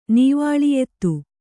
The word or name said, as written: ನೀವಾಳಿಯೆತ್ತು